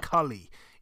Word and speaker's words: Cully: I'll say this, cause you were saying Gary Coley. I was wondering who that was. Gary Cully. Yeah. I know who you're talking about. – Oh, I'm sorry. Yeah. I pronounce it Cully.